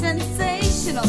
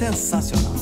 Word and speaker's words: sensacional: Sensational. Hey, it's just like English. – Well, let me tell you. It's pronounced sensacional. Sensational. – Sensational. – Well, not really.